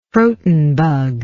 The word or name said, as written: croton bug